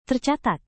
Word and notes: tercatat: indonesian

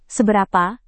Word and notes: seberapa: indonesian

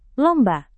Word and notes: lomba: indonesian

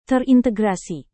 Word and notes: terintegrasi: indonesian

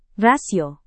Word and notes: rasio: indonesian

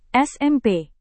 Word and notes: smp: Indonesian

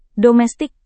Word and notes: domestik: Indonesian

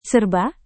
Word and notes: serba: indonesian

